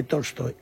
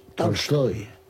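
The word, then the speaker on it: Tolstoy (Толстой): No es exactamente Tolstoy, es Tolstoy.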